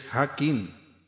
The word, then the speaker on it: Jakin: Esa palabra de poder o mantra es Hakin. Se escribe J-A-K-I-N. Sin embargo, se pronuncia en vez de J como una Y. O I sola.